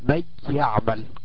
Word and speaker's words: من يعمل: قلنا مع الياء هتقلب، وكأن النون الساكنة أقلبت لياء ساكنة، تضغم في الياء المتحركة ويصير حرف واحدة مشددة زي كلمة من يعمل. من يعمل، أي مي يا ساكنة، يا يعمل يا مفتوحة، تنطق مي يا مي يعمل.